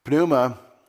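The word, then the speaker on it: Pneuma (πνεῦμα): Pneuma, P-N-E-U-M-A, the Greeks would pronounce the P. P-N-E-U-M-A, pneuma is wind or spirit or breath or worldview or personal being who is only spiritual, who is only spirit.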